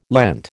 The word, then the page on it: Lent: American English